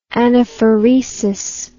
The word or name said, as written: anaphoresis